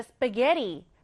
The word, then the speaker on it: spaghetti: 자, 스파게티. 스펠링 한번 체크해 볼게요. spaghetti. 조금 특이해 보입니다. 여러분, 읽을 때는요, 강세를 게이 부분에 두시면 돼요. 그럼 읽을 때 스파게티. 스파게티 이렇게 발음이 되겠죠?